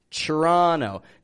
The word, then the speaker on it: Toronto: Ch- Toronto. Now Toronto is spelled T O R O N T O but I'm saying tra, Toronto.